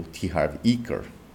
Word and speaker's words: T. Harv Eker: Harv Eker, por exemplo, ou T. Harv Eker, na pronúncia correta, é, não adianta você comprar Os Segredos da Mente Milionária.